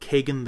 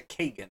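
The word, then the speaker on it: Kagen: But one that was particularly interesting to me was a book that is just about to come out called Kagan the Damned. That's K-A-G-E-N. Maybe it's Cajun? I don't know. – I actually have a friend with that name. It is pronounced as a Kagan.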